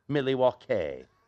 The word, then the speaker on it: Milwaukee: Actually, it's pronounced Milwaukee, which is Algonquin for the good land.